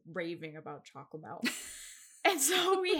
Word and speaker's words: Chocomel: It's a can of, I'm going to pronounce it wrong, I'm sure, Chocomel? – Yeah. – Yeah, Chocomel. Yeah.